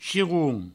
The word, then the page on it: chiron: chiroun; provençal